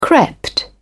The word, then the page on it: crept: British English